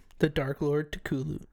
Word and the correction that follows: Cthulhu: Cthulhu. It's a confusing word. – I've had a few, Lou. – It is a confusing word. – It is. Break it down phonetically for me. It's C-T-H-U-L-H-U.